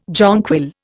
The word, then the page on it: jonquil: Indian